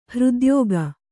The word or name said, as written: ಹೃದ್ಯೋಗ